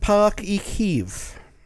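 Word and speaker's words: Páirc Uí Chaoimh: Okay, it's pronounced Parc y Cyhyd.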